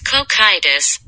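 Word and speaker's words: Cocytus: He was the one that led the siege towards the lizard realm or lizard race, which Kokiotis? How do you pronounce it? It's like Kokitus. Kokitus? – It's Kokitus, bitch.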